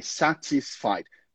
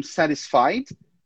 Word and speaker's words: satisfied: Por causa do sotaque britânico, eu acabo pronunciando aquele T, satisfied. Mas no sotaque americano seria algo como satisfied, satisfied. Mas as duas pronúncias são corretas. Você não vai ter problema usando nenhuma delas.